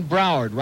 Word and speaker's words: Broward: 751 WIOD, an open line in Dade, and one in Broward, rhymes with coward.